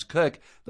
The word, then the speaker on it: Koch: Or my last name, spelled K-O-C-H, which we pronounce Cook, that's just bizarre, which is why I go by Dr. Cathy professionally.